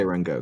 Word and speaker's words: Rengoku: You say Rengoku. – Just Rengoku? Okay. – Yeah. I when this was brought up and somebody said Kyojuro from Demon Slayer, I was like, who? okay. Rengoku is the name they use.